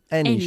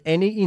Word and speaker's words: any: I don't want any sugar, okay? Muy bien. Y se pronuncia any y no any.